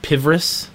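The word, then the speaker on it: Pvris: I believe it's actually pronounced Pivris.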